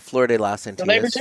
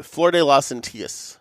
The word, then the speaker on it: Flor de las Antillas: Now what is the My Father I like that I can never pronounce? – The Flor de las Antillas. – Thank you. Flor de las Antillas.